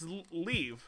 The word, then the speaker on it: Liev: It's Liev. – How do you pronounce that guy's name? – It's Liev.